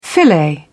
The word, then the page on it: filet: British English